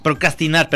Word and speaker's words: procrastinar: Hasta luego Pero creo que no lo estabas pronunciando bien, ¿eh? O sea, estoy. Ah, procranistar. Procranistar. No. Procrastinar, perdón.